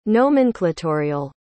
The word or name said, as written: nomenclatorial